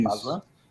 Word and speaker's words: Bazin: Essa frase é do André Bazin, se eu não me engano, acho que eu é pronuncio assim, né? Isso, Bazin. Isso.